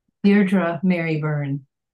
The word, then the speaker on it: Deirdre Mary Byrne: Deirdre Mary Byrne.